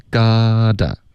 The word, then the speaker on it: gotta: 对所以这里我们的 you gotta got to 我们就发 gotta